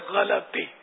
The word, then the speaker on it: غلطی: غلطی, ماشاءاللہ, صحیح تلفظ ہے غلطی. غلط, غلط یعنی غلط.